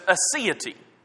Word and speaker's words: aseity: The word is aseity. We're familiar with the word deity. It certainly means God. Aseity is spelled in a similar way, A-S-I-E-T-Y.